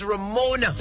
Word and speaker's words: Ramona: My given birth name, it's Ramona. R-A-M-O-N-A. A lot of people spell it R-O-M-O, which is incorrect. My name is spelled R-A-M-O-N-A.